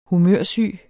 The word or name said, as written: humørsyg